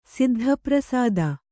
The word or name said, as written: ಸಿದ್ಧಪ್ರಸಾದ